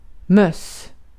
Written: möss

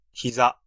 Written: genou

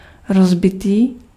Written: broken

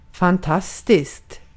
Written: fantastiskt

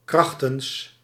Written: under

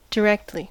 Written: gerade

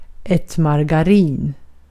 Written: margarin

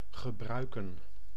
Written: boire

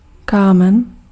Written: draught

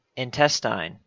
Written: intestine

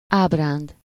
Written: fancy

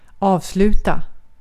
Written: avslutat

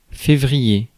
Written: février

